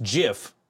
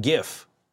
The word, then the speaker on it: GIF: Like for me, I will argue to the death that it's pronounced "jif," not "gif." That is the hill I will die on.